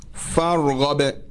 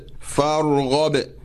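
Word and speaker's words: farghab (فارغب): So for this, we spell it farghab. Then you say fa fatha alif al wasl ra sukun far ghain fatha ba sukun ghab far ghab. I hope the listeners do not forget the letter of qalqala, which is ba.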